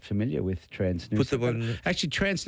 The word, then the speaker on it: Nusa: I'm flying to Bali in a couple of weeks and travelling with Noosa, N-U-S-A. Is that how you pronounce it? Noosa? – Noosa, yeah, Noosa.